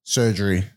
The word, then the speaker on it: Sergerie: Adam Surgery. Our GM. – Oh. – Surgery? – Yep. – Like as in surgery? – Yeah. It's spelled, not spelled like that. – S-E-R-G-E-R. – S-E-R-G-E-R-I-E.